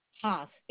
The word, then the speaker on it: Haas: M-Y-R-T-L-E, Myrtle. – Last name? – Haas, H-A-A-S as in Sam. – H-A-A-S, correct? – Yes.